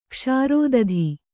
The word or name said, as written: ಕ್ಷಾರೋದಧಿ